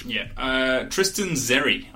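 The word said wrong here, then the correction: Xerri: I think that's how you pronounce it. – Cherry. There we go. Cherry. Tristan Cherry.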